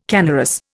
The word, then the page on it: canorous: Indian